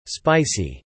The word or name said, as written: spicy